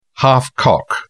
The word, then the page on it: half-cock: British English